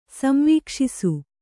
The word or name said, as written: ಸಂವೀಕ್ಷಿಸು